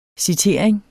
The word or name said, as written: citering